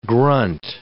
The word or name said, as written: grunt